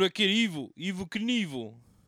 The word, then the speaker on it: Knievel: Also, he pronounces it Knievel. His name's Robert. – Yeah. – Robert Craig. Craig, known as Evil Knievel. – Evelyn? – Evil Knievel. That's how you say it.